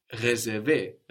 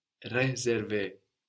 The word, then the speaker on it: réservé: where the E of réservé, réparé, is written with a little accent over it, a little dash going up from the E, which lets you know to pronounce a stronger E, réservé, réparé.